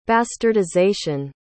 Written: bastardization

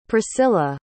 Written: priscila

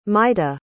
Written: maita

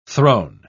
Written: throne

